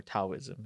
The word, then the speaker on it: Taoism: Yeah, it's the book that's what Taoism is based off, or Taoism. It's spelled with a T, but it's pronounced with a D.